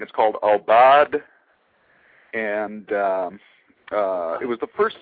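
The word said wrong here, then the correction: Aubade: since, did I pronounce it correctly, or somewhat correctly? – Pretty close, Obad. Obad.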